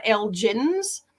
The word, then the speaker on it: Elgin: but all the rest of them are Elgins. – Right. – Elgin. But all the ones with a hard G like we have people from this area in their founding fathers of their town.